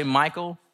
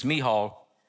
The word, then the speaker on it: Michal: Some say Michael, some say McCall, and the Hebrew translation is Michal.